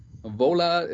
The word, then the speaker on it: Vola: Vola, ist das korrekt ausgesprochen? Ja. Vola ähm, äh, und äh, Shaman mit dem Album Witness.